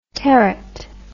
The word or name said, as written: territ